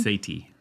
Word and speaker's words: Sethi: How do you say his last name? Sethi.